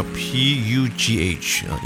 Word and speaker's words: Pugh: 那他的姓呢还蛮特别的，叫 P U G H，应该是发音叫 Pugh。